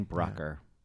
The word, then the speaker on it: Brucker: Jane Brucker. Yeah. Oh, rhymes with Trucker.